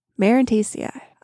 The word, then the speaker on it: Marantaceae: Marantaceae. I had to look up how to pronounce that.